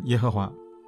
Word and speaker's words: 耶和华: 今天我们给亚威加上主 Adonai 的元音，就变成了亚威，读成耶和华。